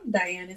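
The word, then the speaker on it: Dianne: Diane is spelled with 2 N's because my parents wanted to make my life difficult. So it's D-I-A-N-N-E, Diane's Vegan Kitchen. On Instagram, I am at Diane Wenz, D-I-A-N-N-E-W-E-N-Z.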